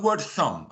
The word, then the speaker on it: thumb: And you say to children, like the word thumb, okay, thumb. You can't hear the B of thumb at the end.